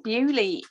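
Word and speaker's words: Beaulieu: Bewley. – Sounds like it should be. It looked like it. – Bewley. – French name. – Yeah, Bewley.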